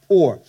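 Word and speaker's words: pour (πῦρ): And, and, and actually, fire actually in Greek is the word pour, P U R, it's pronounced pour.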